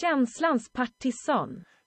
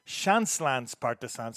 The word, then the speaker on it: Känslans Partisan: Känslans Partisan. – Känslans Partisan. So that's the better Swedish pronunciation. I'm just looking at it like it's an English word and saying it. That's not accurate.